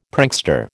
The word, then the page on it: prankster: American English